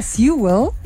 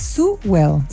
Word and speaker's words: Sewell: Y seguimos hablando con arquitecta de la Fundación Sewell. Oye, que me van a retar porque me cuesta. Yo le decía Sewell, pero es Sewell.